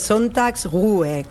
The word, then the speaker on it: Sonntagsruhe: A ver, el Sonntagsruhe, es una palabra difícil de pronunciar aún así, es Sonntagsruhe, que al final es descanso dominical, básicamente.